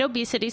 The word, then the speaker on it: Obey City: Say it obesity so you sound cool, but when you have to look it up on the internet, it is Obey City.